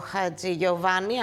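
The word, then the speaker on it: Χατζηγιοβάνη: Τι έγινε, τι γίνεται με τον Τάσο Χατζηγιοβάνι, αν το λέω, αν το τονίζω σωστά. – Πολύ σωστά το τονίζεις.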